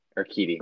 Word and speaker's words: Urquidy: Urquidy, that's right. – Urquidy.